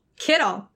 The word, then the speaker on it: Kittle: Rhymes with a famous candy that Marshawn Lynch loved. – Kittle.